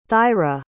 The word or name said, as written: thyra